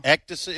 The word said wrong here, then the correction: ecdysis: Is it ecdysis or ectasis? – I always, in class, always pronounced it ecdysis. But I'm going with you.